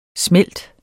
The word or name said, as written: smelt